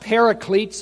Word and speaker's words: paraclete: And again, I'm not sure how much he went into this last week, but the Greek word is pronounced paraclete.